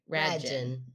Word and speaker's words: Regine: Pronounced rad like cool and gin like the drink. Rad gin.